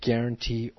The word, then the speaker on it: guarantee: Remember, G-U, like guarantee, or other words like guard, you just pronounce guard and guarantee. You do not pronounce the U.